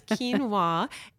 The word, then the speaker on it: quinoa: You don't even know how to pronounce it, um, but it's it's quinoa.